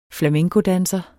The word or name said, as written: flamencodanser